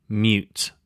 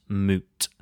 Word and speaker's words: mute: Mute. Mute. To silence a microphone's operation. Now pay attention here, the pronunciation is mute with a U sound, not moot.